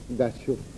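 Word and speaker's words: gachó: y el valor de la J, en inglés no existe J, sino tiene un sonido de eye palatal, entonces lo pronuncié gacho, que es como suena.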